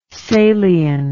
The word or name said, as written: salian